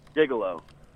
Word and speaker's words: gigolo: Gigolo. – Gigolo. Great gigolo. – Word. – Gigolo. – Great word. – Well, I think I have a shot at gigolo. It's a soft G. Okay, G-I-G, Jiga. Oh, wait, it doesn't look right. G-I-G-I-L-O, could it be that easy? – Don't say it.